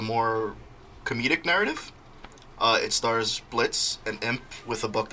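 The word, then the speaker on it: Blitzø: First, we have Blitz. B-L-I-T-Z-O, but the O is silent.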